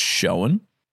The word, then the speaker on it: Schoen: Josh, your surname, if you're comfortable with me giving that away, is it pronounced Schoen? – Yeah, it's pretty much like shown, like as shown here on this helmet's picture, whatever it may be. Kind of like when you're at school.